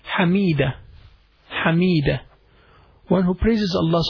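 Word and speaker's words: Hameeda: Hamida.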